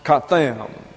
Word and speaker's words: chatam: The word seal is the noun katham. C-H-A-T-A-M, pronounced katham.